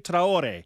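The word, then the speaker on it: Traoré: And for what it's worth, Alex, the more native pronunciation is Fusini Traore.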